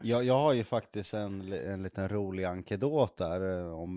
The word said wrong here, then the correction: anekdot: Ankedot, ankedot, vad heter det? Hur uttalas det? – Anekdot.